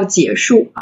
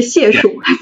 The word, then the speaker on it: 解数: 然后所以呢，我们才起名叫解数啊，不是谢数，因为这个是多音字。就很多很多这个，包括我们的客户，第一次跟我们聊天的时候说啊，解数咨询，因为使尽浑身解数。